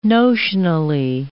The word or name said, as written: notionally